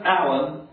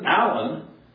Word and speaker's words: Alan: It's Kenneth Allen Weimer, and even Allen is spelled specifically A-L-A-N, not A-L-L-E-N.